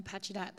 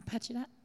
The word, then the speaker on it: Pacheedaht: I'm really glad that you mentioned um, the Pacheedaht. That's how you say it, yeah? Pacheedaht? – Yeah.